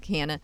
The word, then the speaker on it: Kanin: One of the startups you've backed is called Canon. Am I saying that right? K-A-N-I-N. – Yeah. – Canon.